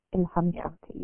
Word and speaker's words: Ilham Tohti: I wondered if you could share some of how, and I don't think we said your father's name, but Ilham Toti, am I pronouncing that correctly? – Yes, perfect. – Yeah. – Ilham Toti, yes.